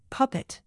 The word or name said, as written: puppet